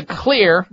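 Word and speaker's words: Xlear: If you're a person who develops colds and flus or sinusitis or sinus congestion, you're probably a candidate for the Clear, remember it's spelled with an X, X-L-E-A-R, the X is for xylitol, Clear Rescue nasal spray.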